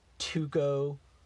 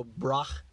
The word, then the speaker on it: Tycho Brahe: His name, I'm sorry, there's so many ways to pronounce his name and to mispronounce his name. So I'm going to mispronounce his name as Tycho Brahe. Some people say, like, Tuco. – Or I've heard Tycho. – Tycho, and there's also Brach.